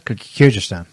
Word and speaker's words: Kyrgyzstan: How do you pronounce that? – Kyrgyzstan.